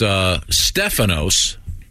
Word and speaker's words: Stefanos: It's Stefanos. – Oh. – Accent first syllable.